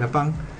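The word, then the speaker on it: lapin: Conejo en lapín, en francés se dice lapín, se escribe lapín, es el lapán.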